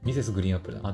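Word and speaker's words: Mrs. GREEN APPLE: ミセスグリーンアップルだ。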